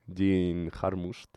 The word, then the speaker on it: Jim Jarmusch: El director este, Jim Jarmusch.